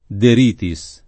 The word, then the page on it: De Ritis: lingua italiana